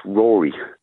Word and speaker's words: Rory: But Spaniards can't pronounce Rory because they roll their R's.